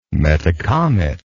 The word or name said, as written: Metacomet